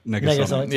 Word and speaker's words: Negasonic: Negasonic.